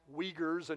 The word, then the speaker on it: Uyghurs: It's spelled U-I-G-H-U-R-S, but it's pronounced Uyghurs.